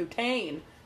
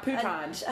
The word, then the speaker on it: poutine: Make sure you say it right. – I can't say it right. – Yeah, you can. – I'm not even going to try. – Poutine. – Anyway. – Not Pouton.